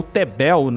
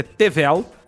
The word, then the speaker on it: Tevel: E aqui o nome é o Tevel, ou Tebel, né? Que eu tava até conversando com o Samuka antes aqui, que na verdade a pronúncia é Tevel, com V. Mas por causa da tradução, provavelmente veio com B, né? – Sim.